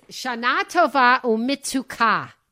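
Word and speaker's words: Shana Tova Umetuka: It's Shana Tova Umetika. The emphasis is on the last syllable.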